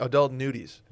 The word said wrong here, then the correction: nudity: Say nudity. – Nudity. – It's three syllables.